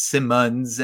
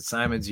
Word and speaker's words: Simonds: It's Simmons, S-I-M-O-N-D-S, You can pronounce it Simons.